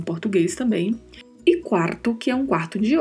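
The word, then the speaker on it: cuarto: Quarto, que aqui no contexto da nossa frase refere-se a hora, é um quarto de hora, ou seja, 15 minutos. A pronúncia de quarto é com este R bem vibrato, né? Quarto. E é bem pronunciada a primeira sílaba, quarto.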